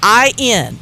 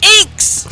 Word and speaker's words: INXS: When the band's name is NXS, INXS. It is not freaking pronounced Inks. Don't Inks. Ever say Inks.